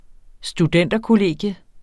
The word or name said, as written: studenterkollegium